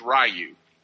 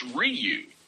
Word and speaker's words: Ryu: Everyone thinks that his name is Ryu. R-Y-U, one of the main characters in the video game. But apparently it's Ryu is the way it's pronounced. R-E-E-O-O, like Ryu, Ryu.